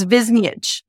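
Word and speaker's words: Goran Višnjić: Stars Abigail Spencer, Matt Lanter, Malcolm Barrett, Patterson Joseph, Sakina Jaffrey, Claudia Dumit, and Goran Biznich, which I just realized I've been saying his name wrong for years. So yeah, I think I always called him Biznich. – I think I was calling him Vijiznik. It's Biznich. Okay. – Biznich.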